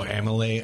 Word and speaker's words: amylase: Oh, amylase.